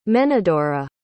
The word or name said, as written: menodora